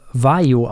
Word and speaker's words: Vaglio: Nämlich, ich habe mir sagen lassen, das wird wohl Vaio ausgesprochen.